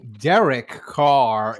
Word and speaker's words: Derek Carr: They are bringing Derek Carr in.